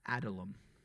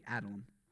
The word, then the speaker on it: Adullam: Adullam. Or that's the way I pronounce it. Maybe you pronounce it differently. Adullam.